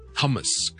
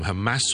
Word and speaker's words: Hamas: 哈马斯呢 ,H-A-M-A-S, 重音放在后面，听着是 Hamas, Hamas, Hamas, OK? Hamas。那个 A 音呢是 Okay, so Hamas 就唔好将佢读成 Hummus 咁，你觉得 Hummus 咧就会变咗系点嘢嘅一啲豆浆咁，就 Hummus。但系咧，哈马斯呢个组织我哋叫做 Hamas，okay，Hamas。咁另外同 Hamas 相关嘅一个字咧就系 Palestine，巴勒斯坦，P-A-L-E-S-T-I-N-E，Palestine。